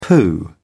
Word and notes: pooh: British English

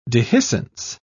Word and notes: dehiscence: British English

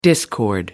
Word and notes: discord: American English

